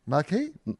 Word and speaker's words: Marquis: So after King, Queen, Prince, Princesses, it goes Duke, Marquis. Is that how you pronounce it? – Oh, yeah. Like the Marquis de Sade, right? Marquis?